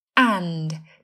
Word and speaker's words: and: từ and thường được phát âm là an, không có âm t.